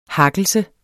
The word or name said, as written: hakkelse